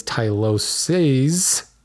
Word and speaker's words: tyloses: And probably I should be referring to it as Tyloses, singular being Tylosis, plural being Tyloses.